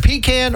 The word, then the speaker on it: pecan: The word is P-E-C-A-N. – Yeah, and people pronounce it differently depending on what you grew up with, where you're from. And it is either pecan or it is, as we grew up, pecan.